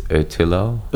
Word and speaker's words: Ötillö: I keep calling it Ötillö, but I think the pronunciation is Ötillö. Do you know how they say it? – I think with the umlaut on top, it's Ötillö.